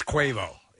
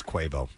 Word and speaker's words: Quavo: Well, we learned it's Quavo. – It is Quavo. – Mm-hmm. Yeah. – Which, if you're going to spell that phonetically, it should be K-W-A-Y, not K-W-E-I, Quavo, and whatever.